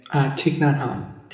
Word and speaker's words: Thich Nhat Hanh: Thich Nhat Hanh. – Thich Nhat Hanh? – Yeah.